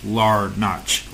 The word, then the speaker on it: Larnach: I always want to put the Larnach, but it's Larnach. Larnach.